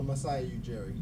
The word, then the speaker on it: Masai Ujiri: How do you say the owner's name of Toronto? The general manager or whatever? – Oh, Masai Ujiri.